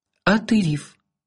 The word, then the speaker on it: Атериф: «Атериф», — медленно, четко, с ударением на последнем слоге воспроизвела она.